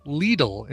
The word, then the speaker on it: Lidl: It is pronounced Lidl in Germany. – Lidl. – Where it comes from.